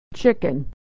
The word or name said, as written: chicken